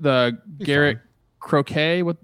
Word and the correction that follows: Crochet: how do you say that guy's name? – I think it's Crooked Face. – Crochet.